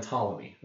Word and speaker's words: Ptolemy: On the other hand, you had the geocentric model, which going back even further, you had Ptolemy, right? With a silent P. For planet or something.